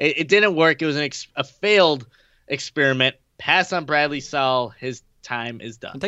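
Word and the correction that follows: Sowell: Technically, isn't it Soul? – Soul. Well, I said Sal. Yeah. I was like, that's not right, Will.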